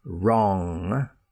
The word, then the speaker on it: wrong: An unpronounced or silent W is most commonly followed by the letter R. For example, wrong. You got the wrong answer. Wrist on the end of your hand. Right I got the wrong address. I broke my wrist. I'll have to write with my other hand. Wrong wrist right. All with that R sound, the W silent.